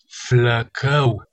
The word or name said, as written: flacau